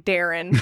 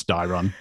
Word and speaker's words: Dairon: So I was making fun of this name in one of the previous chapters because it just sounds like Darren. – No, it's Dairon.